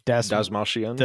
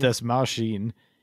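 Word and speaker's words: Dastmalchian: Dasmalshian. – Dasmalshian.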